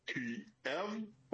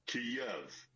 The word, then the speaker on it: Kyiv: Now, the Russian pronunciation is Kiev. The Ukrainian, closest approximation that we can do as non-Slavic speakers is Kiev. So the Y ends at the first syllable.